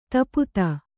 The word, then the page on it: ಧೂಪಿತ: kannada